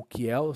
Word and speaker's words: Kiel: Temos outras também, como a classificação de Kiel, ou Kiel, se escreve K-I-E-L, tá?